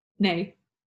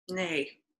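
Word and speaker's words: nej: How do you say no? – Nay. – Nay. Mm-hmm. I say nay.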